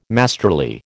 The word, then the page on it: masterly: American English